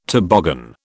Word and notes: toboggan: British English

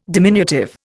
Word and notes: diminutive: Indian

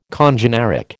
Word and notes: congeneric: American English

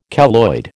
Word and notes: keloid: American English

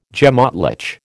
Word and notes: gemÁtlich: American English

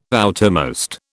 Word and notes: outermost: British English

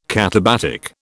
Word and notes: katabatic: British English

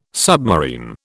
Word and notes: submarine: British English

